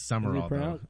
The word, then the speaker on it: Sumrall: Is that how you say it? He's new. Sumrall. – I know it's weird. It is Sumrall. – Is it pronounced Sumrall? – Yeah. – I feel like there's a letter missing. – It is. It's not spelled right, but that's how it's pronounced.